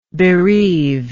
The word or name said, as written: bereaver